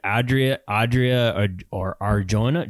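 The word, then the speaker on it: Adria Arjona: Adria or Jonah? Jonah? – No, you got it right.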